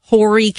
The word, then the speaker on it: Horry: Yes, we did not pronounce Horry County correctly. – Oh, our texters let us know the H is silent.